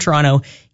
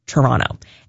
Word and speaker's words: Toronto: Everywhere else, people pronounce the second T because there is a T there. It is technically Toronto, but if you're from Toronto, you just kind of say Toronto.